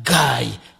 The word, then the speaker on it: Guy: But when I'm outside, I meet someone 18 years old who called me that guy. And I say, thank you very much. My name is Guy, just pronouncing in English.